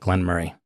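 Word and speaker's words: Glenmoray: It's actually Glenmurray 100 proof rye cask finish. And Murray, it's spelled M-O-R-A-Y. You kind of want to say Murray, but everything that I've seen, they always call it Glenmurray.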